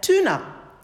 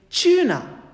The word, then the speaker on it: tuna: Americans pronounce it as tuna, while British pronunciation is tuna.